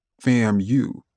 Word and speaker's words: FAMU: We called our school FAMU, pronounced as in family, FAMU.